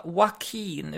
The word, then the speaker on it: Joaquin: Joaquin uttalas hans namn. – Okej. – Okay. Jag har ju sagt olika Joaquin och så, men... – Joaquin? – Ja, Joaquin uttalas det tydligen.